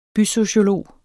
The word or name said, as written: bysociolog